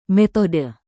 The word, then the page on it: metode: Indonesian